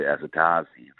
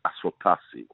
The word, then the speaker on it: Asotasi: And the play, it went through, you know, various players, like, you know, they used to call him Roy Azatazi, for example. You know, it's Asotasi, you know.